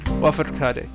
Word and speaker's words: Lafourcade: Now, Mexican singer-songwriter Natalia Lafourcade. – Lafourcade. Okay, there we go. Now I'll pronounce her name properly.